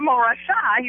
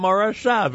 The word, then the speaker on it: Morashah: But also, speaking of Camp Morashah, he's also one of the musical guests at the big concert coming up in February. – And I like how you pronounce that, Camp Morashah. Very nice.